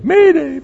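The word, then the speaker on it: mayday (μηδέ): Almost sounds like mayday. Mayday, mayday. M long E D E.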